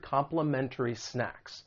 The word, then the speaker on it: complimentary: Now, when it comes to pronunciation, very quickly, I just want to let you know, because I'm from the United States, sometimes people will drop that T because it follows the N sound and it's going to sound like complimentary.